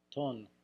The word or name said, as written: ton